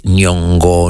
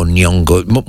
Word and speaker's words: Nyong'o: Lupita, io dico Ngoio, ma in realtà poi è difficile pronunciare, perché sarebbe Nyong'o, Nyong'o, insomma, vabbè, Lupita, chiamiamola Lupita, così è bellissima e bravissima.